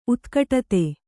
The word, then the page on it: ಉತ್ಕಟತೆ: utkaṭate